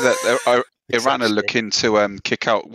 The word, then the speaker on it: Iran: Because first of all, you pronounce my country's wrong. It's Iran, not Iran.